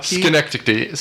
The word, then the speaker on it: Schenectady: Snooki, New York. Did I pronounce that right? – Say that again. – Synecdoche? – Schenectady. – Schenectady.